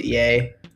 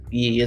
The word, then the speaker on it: Ye: I pronounce it Ye, but E is also how you can say it.